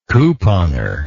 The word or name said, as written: couponer